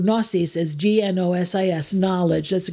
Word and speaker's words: gnosis: The G is silent. In Greek, we'd pronounce the G. We'd say gnosis. In English, they drop the G, it's silent. But in Greek, we would say gnosis.